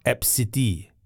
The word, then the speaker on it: ABCDE: Yeah, it's pronounced Epsity.